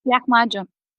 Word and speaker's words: Lahmacun: Wie spricht man das richtig aus? – Lahmacun. – Lahmacun.